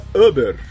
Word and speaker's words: Uber: Gostei da pronúncia, Uber. – Uber. Eu tô fazendo um intensivo de alemão.